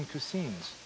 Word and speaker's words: cuisine: New Mexico and Mexican cuisines. Cuisines. How do you pronounce that? – Cuisine.